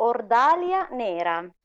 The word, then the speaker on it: Ordahlia Nera: Hai detto giusto, Ordalia Nera.